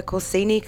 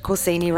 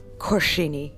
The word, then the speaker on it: Corsini: She's going to tell us about the gallery's upcoming show, the Corsini Collection. Have I pronounced Corsini right? – Yeah, that's exactly right.